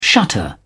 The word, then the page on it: shutter: British English